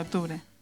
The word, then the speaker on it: octubre: No puedes obviar la C de octubre.